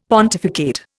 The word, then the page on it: pontificate: Indian